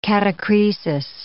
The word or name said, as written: catachresis